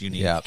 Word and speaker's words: Yannick: Once the stuff about his name being pronounced Unique came out, it's all been downhill.